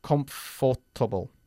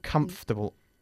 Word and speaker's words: comfortable: We don't say comfortable. Yeah, comfortable. Omitimos la OR cuando lo decimos. Comfortable.